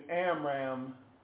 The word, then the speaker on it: Amram: Amram, I can't pronounce it. Amram, A-M-R-A-M, I think that's how you spell it.